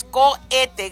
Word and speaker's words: cohete: Es COHETE, COHETE, pero no pronuncian el H, nomás COHETE. Gracias, compadre, gracias también a la señora María que... pues me hablaron. Dice, pues en mi defensa, pues si lo veo, así lo voy a pronunciar. So, ya sé de que el H no se pronuncie.